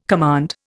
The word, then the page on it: command: Indian